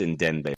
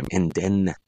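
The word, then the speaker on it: Ndenbe: Ndembe. I can never pronounce Logan's name. I should just call him Ndembe. – Logan. Yeah, there's no M in there, but I make it sound like there is.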